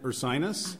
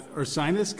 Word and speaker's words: Ursinus: You went to college at Ursinus. – You pronounced it correctly. – I barely did. – Yes. – Ursinus College in Pennsylvania.